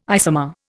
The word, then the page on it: isomer: Indian